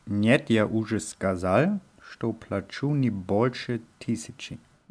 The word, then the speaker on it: плачу: Я плачу heißt ich zahle. – Я плачу. – Und hier ist die Betonung auf dem U sehr, sehr wichtig, weil ja плачу mit Betonung auf A heißt ich weine.